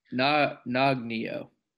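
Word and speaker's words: Nøgne Ø: It is, I pronounce it Noggin. Nogginyo? – Nogginyo? – N-O-G-N-E-O? – Nogginyo. – Nogginyo. Okay, we'll go with that. – Nogginyo. – It is a Norwegian.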